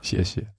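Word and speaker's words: Xie, xie (谢谢): Wie spricht man es richtig aus? Xie, xie.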